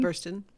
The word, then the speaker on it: Burstyn: Burstyn.